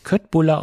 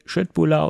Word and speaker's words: Köttbullar: Ich habe extra recherchiert, ob es nicht Köttbullar ausgesprochen wird. Nein, es wird Schöttbullar ausgesprochen. Das ist die richtige Aussprache.